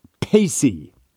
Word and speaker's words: pace: He said I pronounced pace wrong, meaning as per or the Latin use of pace if you've seen it in writing, which means something like to take a kind exception to or acknowledging the exception of so-and-so. And John writes in and says it's pronounced pacey.